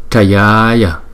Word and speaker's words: Trayaya: Thì là chữ này đọc là Trayaya, thấy không? Trayaya, chứ không phải là Trayaya, thấy không?